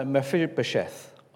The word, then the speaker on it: Mephibosheth: Remember when, and Guy spoke to us a few weeks ago about this, remember when Mephibosheth,